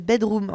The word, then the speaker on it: bdrmm: Eh bien, on continue avec un groupe qui s'appelle Bedroom. Enfin, je devine qu'il s'appelle Bedroom, parce que du coup, ça s'écrit B-D-R-M-M.